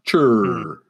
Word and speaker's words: Chur: Chur. – No, chur. Instead of the E, it's got a U. C-H-U-R. Chur.